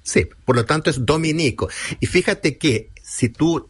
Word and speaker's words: Dominico: Si se alude a los religiosos de la Orden de Santo Domingo, se escribe Dominico, sin tilde en la I.